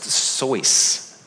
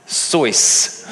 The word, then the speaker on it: Seuss: Dr. Seuss, his name, actually, I learned the other day, is actually pronounced Soyce. Dr. Soyce.